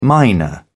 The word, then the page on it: mynah: British English